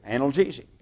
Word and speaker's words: analgesic: Analgesic.